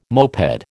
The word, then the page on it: moped: American English